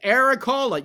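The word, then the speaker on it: Erik Haula: Because Eric Halla, yes, I said that right. Eric Halla scored a goal.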